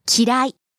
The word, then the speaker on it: 嫌い: 嫌い。 – 嫌い、dislike. – そうですね。 The pronunciation of 嫌い is a bit similar to 綺麗.